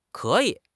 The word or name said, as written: ke3 yi3